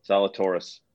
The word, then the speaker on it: Zalatoris: I'm taking Will Zalatoris. – Okay. – Oh, who? – Zalatoris. – Thanks. There you go. – There you go. – Is that the proper pronunciation? – Zalatoris.